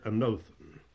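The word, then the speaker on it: anothen (ἄνωθεν): Now, the word again here is the Greek word anothen. I'll spell that for you, A-N-O-T-H-E-N. The O is long, that is, it is omega.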